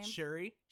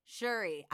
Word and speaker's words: Shuri: Shuri. – Shuri.